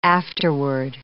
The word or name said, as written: afterword